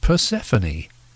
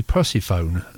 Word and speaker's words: Persephone: We started off with Wishbone Ash from There's the Rub, and a song which I presume is pronounced Persephone. Otherwise, it would be Persephone. So it makes it sound like a wind-up gramophone.